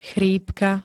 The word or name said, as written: chrípka